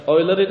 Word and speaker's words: Euler: Kalau kalian bacanya Euler salah. Yang benar tuh Euler. Euler. Euler ya, tulisannya Euler. Iya. Bacanya Euler. Euler. – Karena dari Jerman, apa dari Swiss? Dari Swiss. Ya dekat-dekat lah.